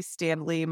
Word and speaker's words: Standlee: Yeah, I'm on LinkedIn, just under Leslie Standlee. My last name is spelled really uniquely. You'll see that Standlee, but you'll be able to find me pretty easily out there.